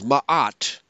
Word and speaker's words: Ma'at: Mat, or two syllables, ma'at, refers to the ancient Egyptian concepts of truth, balance, order, harmony, law, morality, and justice.